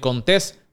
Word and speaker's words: Contés: Mi nombre es Miguel Contés con acento en la E y nos vemos en la próxima.